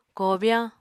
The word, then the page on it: kobyáñ: Apatani